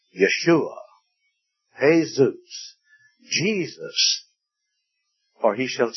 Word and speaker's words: Yeshua: In the Hebrew, it would be Yeshua. We know it in our English translation as Joshua. In Spanish, the J is pronounced as an H, but in Hebrew, it's pronounced as a Y.